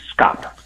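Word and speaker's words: Schaap: Well, the first thing, I'm going to correct the pronunciation. It's pronounced like it would be spelled S-K-O-P, SCOP.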